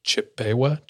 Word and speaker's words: Chippewa: I'm going to do my best to pronounce it, but it's C-H-I-P-P-E-W-A. Chippewa.